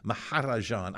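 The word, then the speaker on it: Mahrajan: And I never got it right, and Lou would yell at me every year, you're not pronouncing it right. You're not saying it right. Maharajan.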